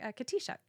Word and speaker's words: Katixa: And I'm like, Katisha. How do you spell it? I'm like, so I spell it, you know, K-A-T-I-X-A. And they're like, oh. Sounds like it's spelled Katixa to me.